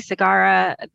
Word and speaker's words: Segarra: It is Lisa Marie Segarra. That's the easy version. You're actually supposed to pronounce, like, roll the R, but... No one ever gets that right.